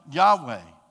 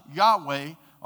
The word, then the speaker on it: Yahweh: We have Yahweh, who is such a sacred name that it doesn't even actually make sense when you see it written, because anyone that would try to pronounce YHWH can't really phonetically do that, but it is pronounced Yahweh, or the Almighty Supreme God.